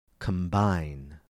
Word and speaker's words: combine: Combine. Notice how the B's in each of these words are pronounced differently. In the word comb, C-O-M-B, the B is silent. In the word combine, the B is pronounced.